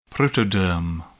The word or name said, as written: protoderm